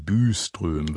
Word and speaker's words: Byström: Eine tolle Sängerin, jedenfalls eine sehr gute, ist die schwedische Sopranistin Malin Byström, Byström wird sie ausgesprochen,